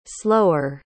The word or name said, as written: slower